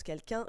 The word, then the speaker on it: QQUN: Par quelqu'un proposé par Anthony, ça s'écrit QQUN, mais ça se prononce quelqu'un. J'ai vérifié, j'ai mes sources.